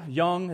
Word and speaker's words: Jung: Jung, it's actually J-U-N-G, but I can't pronounce it, so I'm just going to say Jung from here on out, and you can make fun of my accent later.